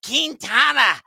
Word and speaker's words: Quintana: And on top of that, he's got a decent history against Jose Quintana. That's the way you got to pronounce it appropriately, from what I've been told, of the New York Mets, who is a left-handed pitcher.